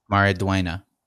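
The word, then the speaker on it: Maridueña: Maraduena. The U-E is pronounced with a yeah sound. – Yeah. – And the N with the thing over it is a yeah.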